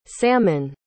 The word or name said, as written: sammon